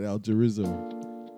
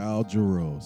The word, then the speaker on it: Al Jarreau: Al Jarreau. That's just, oh, man. – Yeah. – Al Jarreau. So Al Jarreau.